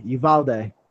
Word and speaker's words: Uvalde: So in Buffalo, as well as this one in Uvalde. – Yeah, I think that's how you say it. – Yeah, Uvalde.